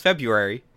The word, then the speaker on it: February: It's got an R in it. – People, it's pronounced February.